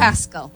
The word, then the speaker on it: Pascal: Pascal. – Okay, Dr. Pascal. – Pascal, like Pascal from Hot Springs, Arkansas.